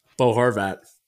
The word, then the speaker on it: Bo Horvat: I said that correctly, Bo Horvat.